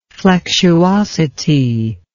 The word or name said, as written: flexuosity